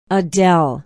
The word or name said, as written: adell